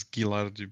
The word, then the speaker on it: Ghilardi: I do have to tell you, it's Ghilardi, because GH together in Italian is like hard G, whereas G before a vowel usually turns into G. So instead of like G, it turns into G. Like Giacomo, for example, it's J-I-A, so it's G. Whereas Ghilardi has an H, therefore it's G.